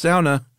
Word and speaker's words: sauna: No, we got when we did our sauna episode, every Finnish person in the world wrote in and said it's pronounced sauna.